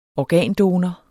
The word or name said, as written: organdonor